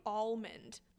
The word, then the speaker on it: almond: I say almond. I'm like, the L is there.